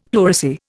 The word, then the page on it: pleurisy: Indian